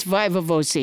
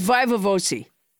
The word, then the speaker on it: viva voce: But you came up with Vosi as your pronunciation. – It's Viva Vosi.